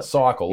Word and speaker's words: Cykel: Cycle, Instagram. Like a cyclist.